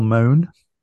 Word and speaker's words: Mone: Um, this week we've got um, a lady called Michelle Mone. M O N E. Be careful how you say it.